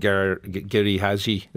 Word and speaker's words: Giri Haji: The Lazarus Project and Giri Haji.